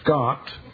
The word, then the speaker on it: Schacht: It's really Dr. Scott, because it's pronounced with the harsh sound, but spelled S-C-H-A-C-H-T.